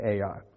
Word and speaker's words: Ai: Now that's the way I pronounce it. I'm pretty sure there's other ways to pronounce it, but it's just two letters and I just say Ai.